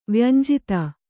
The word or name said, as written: ವ್ಯಂಜಿತ